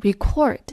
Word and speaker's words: record: 它做动词的发音我们会更熟悉，读作 record。